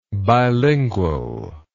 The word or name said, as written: bilingually